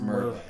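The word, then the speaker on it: Murvagh: M-U-V-R-A-G-H. – But it's pronounced Merva. – M-U-R-V.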